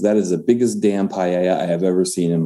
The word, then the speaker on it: paella: Matt, you were cooking with, I'm going to see if I say this right, a paella? – Yeah, paella.